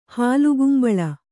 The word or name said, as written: ಹಾಲ್ಗುಂಬಳ